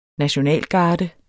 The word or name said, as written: nationalgarde